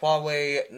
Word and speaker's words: Huawei: Between my phone, the Note 3, which has a micro 3.0 port, and the Huawei Nexus 6... – I think if that's Hawaiian, you're pronouncing it really wrong. – No, it's pronounced Huawei.